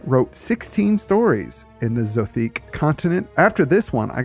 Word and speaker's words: Zothique: And there's a whole collection of Clark Ashton Smith's Zothique stories. I believe that's how it's pronounced.